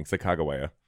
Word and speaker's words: Sacagawea: Oh, that's the one with sack of chihuahua on it. That's what you're saying. Sacagawea.